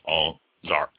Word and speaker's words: Auxarc: And Chateau Ozark is spelled AUXARC, pronounced Ozark, which is very creative and cute of them.